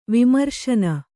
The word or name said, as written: ವಿಮರ್ಶನ